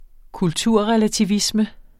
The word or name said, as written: kulturrelativisme